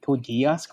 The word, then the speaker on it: Code Geass: How do you say it? Code Geass. There you go.